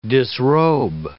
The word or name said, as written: disrobe